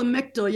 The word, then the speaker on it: Lamictal: And the magical drug that I believe you're on, maybe it's changed, Lamictal, am I saying that right? – It's called Lamictal, yeah.